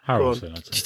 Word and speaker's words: Harrelson: Is it Harrelson or Harrelson? – Harrelson.